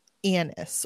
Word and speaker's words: Agnes: I struggled so bad because I did read Agnes. This book. It's Agnes, but they pronounce it Annis or Agnes.